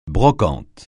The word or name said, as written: brocante